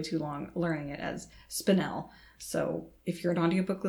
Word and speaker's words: spinel: The audiobook says spinel, like spinal, spinal, spinal, spinel, spinal, spinel. You tell me which one sounds more like a mineral.